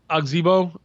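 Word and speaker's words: Ozigbo: His how do you say his last name? Azigbo. – Azigbo.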